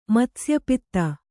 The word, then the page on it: ಮತ್ಸ್ಯಪಿತ್ತ: matsya pitta